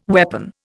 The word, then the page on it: weapon: Indian